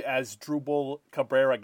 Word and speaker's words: Asdrubal Cabrera: Big Asdrubal Cabrera guy. Actually, you know, I pronounced that one right. I know that one's for sure.